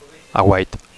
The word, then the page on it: aguait: Catalan